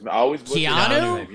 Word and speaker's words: Keanu: I always Keanu. – You know, Keanu. Yeah, that's it. – Okay. – Keanu Reeves.